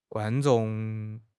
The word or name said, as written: guan1 zhong4